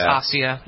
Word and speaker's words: Asia: I say Asia. – It looks like Asia, but it's Asia.